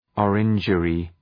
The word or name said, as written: orangery